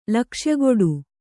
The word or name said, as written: ಲಕ್ಷ್ಯಗೊಡು